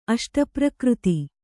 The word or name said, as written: ಅಷ್ಟಪ್ರಕೃತಿ